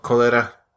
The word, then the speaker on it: Colera: The short is called Cholera, or, cholera. – But it's spelled C-O-L-E-R-A.